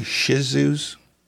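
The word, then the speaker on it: Shih Tzu: Do you ever have any Shih Tzus? Did I pronounce that right? – Yeah, you got it.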